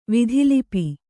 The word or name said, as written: ವಿಧಿಲಿಪಿ